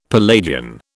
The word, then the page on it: Palladian: British English